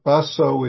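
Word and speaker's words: Baso: Basso is Japanese way of pronouncing his name. In Chinese, it's Ma Tzu.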